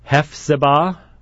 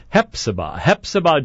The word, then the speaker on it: Hephzibah: If you were listening to the last program before I went off to Georgia, to Hephzibah, I need to apologize, because last week I said Hephzibah, which of course is how it's spelled, but how it's spelled is not how it's pronounced. It is Hephzibah, Georgia, which is south of Augusta.